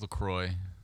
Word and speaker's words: LaCroix: Yeah, and it's LaCroix. That's how it's pronounced.